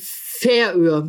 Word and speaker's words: Färöer: Die korrekte Aussprache ist die Färöer. Betonung auf der ersten Silbe.